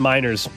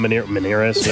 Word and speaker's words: Meiners: How's it spelled? M-E-I-N-E-R-S. Miners, not Meneres. Yeah, I don't know. – Well, we had Melendez, and I thought Miners, it was Quinn Meinerz.